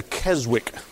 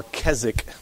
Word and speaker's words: Keswick: Deeper life movement, formerly known as the Keswick movement, or Keswick, but it's spelled Keswick movement,